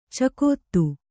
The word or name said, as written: ಚಕೋತ್ತು